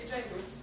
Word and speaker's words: Jehu: Okay, Jehu.